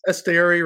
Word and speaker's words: Esteury: Is it Esteury Ruiz? – Yes, Esteury Ruiz, I believe.